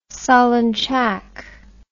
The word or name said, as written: solonchak